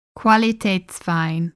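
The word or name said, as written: Qualitätswein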